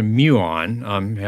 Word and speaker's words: muon: So it's called a muon, after the Greek letter mu. Hardly anybody knows what a muon is, except for a few physicists.